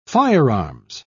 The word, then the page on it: firearms: British English